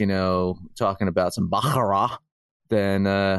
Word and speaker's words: Baccarat: Baccarat.